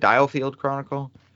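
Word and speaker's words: The DioField Chronicle: Diofield Chronicle? – Yeah.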